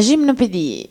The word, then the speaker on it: Gymnopédie: La Gymnopédie. – Gymnopédie, ecco. Mari, vuoi dire qualcosa, che visto che sei una appassionata? – No, no, Erik Satie in realtà mi piace un sacco, in particolare la Gymnopédie numero uno, che faremo sentire, è uno dei miei pezzi preferiti di sempre.